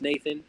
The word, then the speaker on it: NTHN: It goes by it's spelled NTHN, but it's pronounced Nathan.